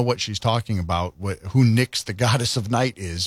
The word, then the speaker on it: Nyx: Nyx, N-Y-X, the goddess of night?